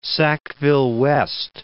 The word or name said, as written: Sackville-West